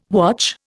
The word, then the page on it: watch: Indian